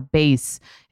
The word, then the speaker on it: BEIS: And right now, Base, it's spelled B-E-I-S, is offering our listeners 15% off your first purchase by visiting Base Travel.